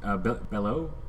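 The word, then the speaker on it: Beaulieu: And so I was listening to Frank Conniff and Trace, how do you pronounce his name? Bellow? Mm-hmm.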